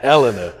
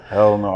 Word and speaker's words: Helena: I fucked up the name when I talked to Freddie. Now I know how to pronounce it. Helena. – Helena, Arkansas.